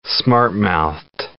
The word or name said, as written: smart-mouthed